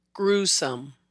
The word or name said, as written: Gruesome